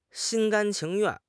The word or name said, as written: 心甘情愿